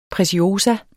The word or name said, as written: pretiosa